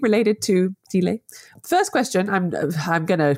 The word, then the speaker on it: stele: Is it Stele? – Stele, yes. – Stella? – Basically, just say Stela or... And that'll work for the singular and the plural. Stella, Steely, okay. Really either way is fine.